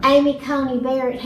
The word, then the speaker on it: Amy Coney Barrett: Amy Coney Barrett.